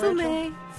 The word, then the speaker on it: fumer: Did I pronounce it right? Do you know, Fumé. – Fumé. Yeah. They don't like their R's in France.